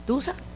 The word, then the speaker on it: tusa: Tusa. – ¿Pero cómo se escribe? – La tusa. T-U-S-A.